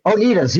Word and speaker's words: Oneida: Back in 1802, most of the men and women from Brotherton left New Jersey to join the Oneidas, which I'm not exactly sure. – Oneida? – The Oneiders? – Oneidas, yes, that's it.